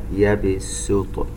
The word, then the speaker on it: يبسط: ننتبه إلى لفظ كلمة يبسط، يبسط، السين المضمومة نشبع الضمة لكن لا ننطقها صاد، لا نقرأ يبسط سين لكنها مضمومة، يبسط.